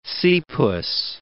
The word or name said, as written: sea puss